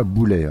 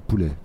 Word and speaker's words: poulet: C'est pas boulet, hein, c'est poulet.